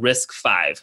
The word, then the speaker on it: RISC-V: You know, there's a very popular open source semiconductor design project called RISC-V, for example. It's spelled RISC-V. So V is the five in terms of Roman numeral.